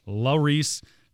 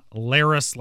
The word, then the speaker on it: Larice: I've been saying that wrong all year long. I was told here recently, Ross, my apologies. It is Larice, not Laris, like I've been mucking it up all year.